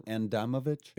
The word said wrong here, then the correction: Adamovich: It's Adamovich. – Adamovich. – Yeah. It's okay. Spelled like Adamovic, like Adam Ovic, but it's Adamovich.